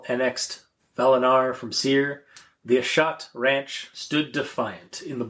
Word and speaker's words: Ashat: This will be Eberron, Ashat's Adventurers. – Ashat's Adventurers. – It's pronounced Ashat. – Pronounced like Shat? – Yeah.